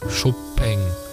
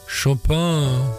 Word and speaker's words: Chopin: In meiner rheinischen Heimat würden wir den Titel aussprechen als I like Chopin. Das war aber natürlich I like Chopin.